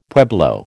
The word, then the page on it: pueblo: American English